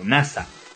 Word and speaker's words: Nasa: La historia sigue a un protagonista cuyo nombre está escrito con los caracteres de Hoshisora, cielo estrellado en japonés, pero cuyo nombre se pronuncia como NASA.